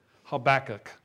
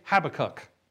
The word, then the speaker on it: Habakkuk: That's how I pronounce it, Habakkuk. Okay, when I was a little kid and we learned the books of the Bible, I learned Habakkuk. Now, I know some of you may say Habakkuk.